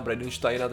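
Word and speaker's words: Bridenstein: Co zatím víme je to, že eh, NASA tady to chce, a vlastně podle už jejich administrátora, že Jima Briden, Bridensteina, Bridensteina, teď jsem si to pouštěl, tu výslovnost, tak uh, oni totiž to chtějí použít v podstatě jako promo.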